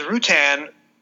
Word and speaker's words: Rutan: And even if the Rutan is Rutan, Tom Baker rhymes it with Reuben.